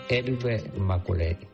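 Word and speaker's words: Herbert Macaulay: Se pronunciaría. Vamos a decirle Maculay. Elber Maculay. Casi como Eberto. Elber.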